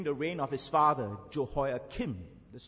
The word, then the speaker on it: Jehoiakim: Some people pronounce Jehoiakim as Jehoiachin, C-H-I-N. While in the Hebrew, it's Jehoiakim.